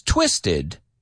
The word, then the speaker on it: twisted: It ends with ED and the ED is pronounced. So I know it's twisted.